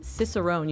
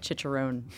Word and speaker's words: cicerone: We have James Watt, who is a cicerone. You just told me how to pronounce this. I was calling it chicharone, but I think that's a pork it's a pork rind.